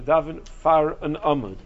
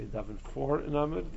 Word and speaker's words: daven far an amud: Generally speaking, if someone asks you to lead the tefillah, to daven far an amud, that's people mispronounce it, they say daven for an amud. There is no such thing in English of davening for the amud. Far means in front of, I think, in Yiddish.